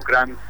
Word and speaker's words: Krantz: Para eso simplemente tienen que buscar el entrada a Bandcamp y acordarse que mi nombre se escribe Pablo Kranz con K y con una Z al final.